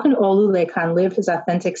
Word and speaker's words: Olulekan: Your friend, whose name is spelled O-L-U-L-E-K-A-N and is pronounced Olulekan, continually is called Olu.